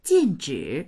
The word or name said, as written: jin4zhi3